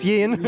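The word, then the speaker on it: Jaehn: So, if you see here in the playlist, you see there's uh, Felix, uh, jo- I don't know how you say his name. – It's Felix Yin.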